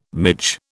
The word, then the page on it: midge: British English